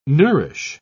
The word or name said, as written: nourish